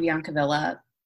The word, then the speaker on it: Biancavilla: Yeah, I mean, on Instagram, I'm at Christine Biancavilla. It's spelled how it is sounded, but anyway.